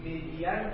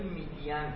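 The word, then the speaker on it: median: میدیان غلطه. میدیان نوشته میشه ولی میدیان تلفظش هست.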